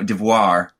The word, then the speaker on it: devoir: No, devoir, sorry. Two very different words. – Devour or devoir, whatever. – Devoir, French for duty.